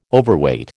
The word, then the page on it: Overweight: American English